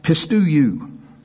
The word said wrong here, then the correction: pisteuo: No, pistuo. Pistuo. P-I-S-T-E-U-O. Let me do that again in case somebody's writing it down. P-I-S-T-E-U-O.